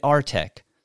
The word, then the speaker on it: artèQ: So, first of all, did I pronounce it correctly? Is it Artec? – Yes. Okay. Uh, we call it Artec U or Artec, but it's very proper.